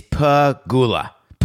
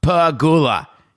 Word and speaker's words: Pegula: When Terry, the owner of the Bills, Pagula, is it Pegula? Pagula? I'm going to get the name wrong. Sorry, Terry. Sorry, Coca. I don't have it. So it is Pagula. Pagula. Thank you, Coca.